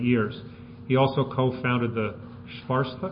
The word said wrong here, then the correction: Svasthya: You gotta tell me how to say it. Sorry, you'll tell us. Svasthya Project, a community health partnership in rural India designed to train women to be health providers and educators.